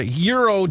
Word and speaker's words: gyro: It is National Euro Day. Now, a gyro is a little thing. It's a thing, too, but you don't pronounce it that way. It's Euro.